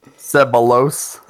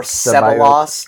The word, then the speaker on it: Ceballos: Arsenal brought in Dani Ceballos on loan from Real Madrid, so. – Mate, your pronunciation is beyond shocking. – Ceballos. Dani Ceballos. – Or Ceballos, depending on how American you want it to be.